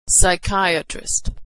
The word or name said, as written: psychiatrist